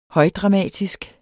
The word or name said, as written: højdramatisk